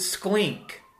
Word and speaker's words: SKLINK: SKLINK is spelled Sierra, Kilo, Lima, India, November, Kilo. You pronounce it SKLINK.